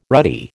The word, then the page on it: ruddy: American English